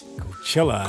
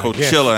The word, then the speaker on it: Coachella: Coachella. – Coachella, I guess.